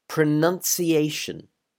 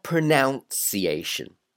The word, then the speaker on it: pronunciation: It is pronunciation. Pronunciation. Not pronunciation. Now, the verb is to pronounce, but the noun is pronunciation.